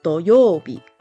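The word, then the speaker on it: 土曜日: Do can be used in doyobi, Tsuchi is another pronunciation, which can be used in the word tsuchiya, which is mud hut.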